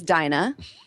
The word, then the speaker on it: Dinah: My name is Dinah. I'm on the spectrum somewhere. It rhymes with vagina.